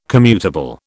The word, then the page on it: commutable: British English